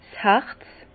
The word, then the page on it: 's Harz: Alsatian; Bas-Rhin; Herrlisheim